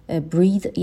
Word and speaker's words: breath: Breathe یک فعل هست, آخرش ای داره به معنای نفس کشیدن. تلفظ به این شکل هستش، breathe، و breath یک اسم هست که آخرش ای نداره، تلفظش هم به این شکل breath به معنای نفس.